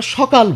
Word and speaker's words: সকাল: আচ্ছা, আমরা সকাল বলছি, লিখছি কি দিয়ে, দন্ত স দিয়ে, লিখছি দন্ত স দিয়ে, হয়ে যাচ্ছে তালবসা উচ্চারণে।